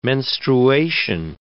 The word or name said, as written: menstruation